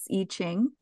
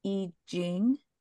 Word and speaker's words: I Ching: The first one is we've had a few responses saying that it is E and not I when talking about how to pronounce I Ching. – Nice. – Yeah, one email we got was from Rebecca N, and she said that technically it's I Ching with a J and not a Ch.